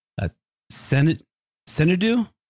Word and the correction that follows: Sentido: Yeah, sentido. Ah, oh, much better.